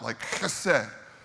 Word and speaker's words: hesed: He studied the Bible all his life, and he's had this love affair with this Hebrew word, hesed. And to say it right, you have to like hock one up, like hesed.